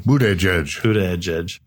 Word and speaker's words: Buttigieg: Okay, so Mayor Buttigieg, however you say his name, Buttigieg.